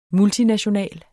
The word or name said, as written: multinational